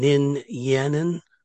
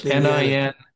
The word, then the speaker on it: Ninyanin: You pronounce the name again, Nin Yanin. How do you go? – Ninyanin, yes, Ninyanin. – N-I-N-A, N-I-N-Y-A-N-I-N, Ninyanin.